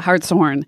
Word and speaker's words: Hartshorne: Hartshorne, yeah, Hartshorne. Not Hartshorne. – Yeah, Hartshorne. It's the horn of a male deer.